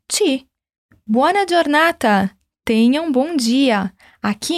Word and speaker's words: giornata: O G e o I juntos em italiano se leem di, como dia. Giornata. – Então, como eu falo tenha um bom dia? Buona giornata.